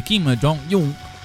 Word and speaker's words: Kim Jong-un: Ed è stata l'occasione che eh, Kim Jong-un ho detto bene Edoardo.